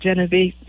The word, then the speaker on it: Genovese: Is that how to pronounce it? – Uh, we say it Genovese. Genovese. – Genovese. It might have been okay. differently pronounced in Italy, but my shout out to my auntie Louise, who's 93.